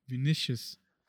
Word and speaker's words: Vinicius: Oh, Vinicius.